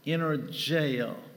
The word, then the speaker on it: energeo: It's spelled E-N-E-R-G-E-O, energeo. Energy. It's an energy word. It's an energy word. Now, I pronounce it energeo, and that's not really the correct pronunciation. That's just the way I like to call it. But the way it's properly pronounced in the Greek is actually energeo.